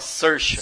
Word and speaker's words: Saoirse: Temos Saoirse Ronan, nem sei se eu pronunciei Sir, certo. Saoirse. Porque, meu Deus. Você fala Saoirse.